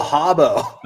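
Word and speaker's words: Rabo: B-R-A-B-O. – Bravo. – Bravo? Am I saying it correctly? – I don't know. – Hobo? Is that Oh, Hobo. – The Hobo, now I know.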